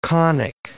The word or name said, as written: conic